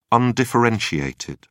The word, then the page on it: undifferentiated: British English